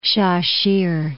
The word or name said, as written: Sha-shih